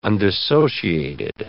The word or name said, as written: undissociated